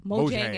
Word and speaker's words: Mojang: Oh, Mojang AB.